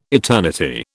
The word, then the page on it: eternity: British English